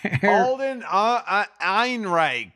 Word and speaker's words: Alden Ehrenreich: So the three movies we're doing this week are The Yellowbirds, which stars Aiden Erickson. – Alden Ehrenreich. – That's it. Yeah. Thank you.